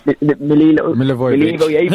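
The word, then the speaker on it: Milivojević: He'll be pushing high up the pitch for hopefully isolating Milelio. I don't even know how to pronounce his name. – Milivojevic. – Yeah.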